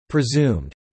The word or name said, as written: presumed